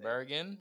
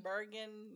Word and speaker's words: Bergen: Bergen. – Bergen, New Jersey.